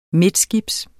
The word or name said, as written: midtskibs